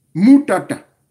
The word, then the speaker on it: Moutata: Voyez-vous, il y a une manière de prononcer, c'est-à-dire qu'il y a des consonnes où on met l'accent. Par contre, le M et le N, c'est des lettres molles. Voilà pourquoi il y a une certaine mollesque, ou comment on a dit ça en français. Il faut le prononcer de manière doux. Mou-ta-ta. Parce que ta, ka, c'est des lettres fortes.